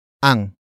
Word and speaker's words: ang: The next word is ang. Ang. Ang. Ang is spelled as A-N-G.